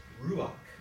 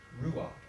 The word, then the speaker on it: ruach: The Hebrew equivalent to that concept, to that idea, to that word, the Hebrew equivalent, the Old Testament, is a word that's pronounced ruach, ruach.